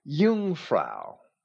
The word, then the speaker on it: Jungfrau: Checking the American Heritage College Dictionary for the pronunciation of the Swiss psychiatrist Carl Jung's last name, we find Jung, Y-O-O-N-G, and the Swiss city Jungfrau is Jungfrau.